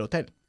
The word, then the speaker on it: hotel: Todas ellas tienen que ver esta semana con el hotel. – All Un hotel. – right. – Okay, ¿cómo se dice hotel en inglés? – Hotel. – Hotel. Eso es, nosotros enfatizamos la segunda sílaba, ¿ok?